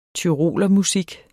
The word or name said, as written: tyrolermusik